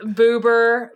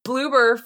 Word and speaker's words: Bloober: And then I click a timestamp, and the first fucking thing I see in the timestamp is the Bloober logo! – Beautiful. Oh, man. – We kept mispronouncing the name earlier, calling them everything but what they actually were. Just complete disrespect. – Boober, booger.